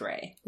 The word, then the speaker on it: Rei: and the hanzi character for her translates to beauty and phonetically is pronounced Rei.